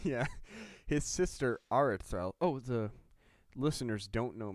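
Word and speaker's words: Aredhel: So about 200 years after Gondolin was created, Turgon's sister, Aradrel. Is that how you say it? – Arathel. – You know, it's tough because there's no TH.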